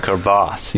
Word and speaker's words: crevasse: You have like a crevice and you have a crevasse, you know? – Right, the crevasse. – That's the difference. – The long A makes it wider.